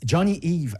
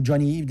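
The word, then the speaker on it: Jony Ive: Johnny Ive, ou Johnny Ive, dépendant de la prononciation, vous le faites en français ou à l'anglaise.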